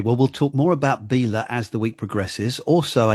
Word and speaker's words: Belaugh: Now, it's spelt B-E-L-A-U-G-H. I thought it was called Belaugh, but it's not, is it? – No, it's Bela.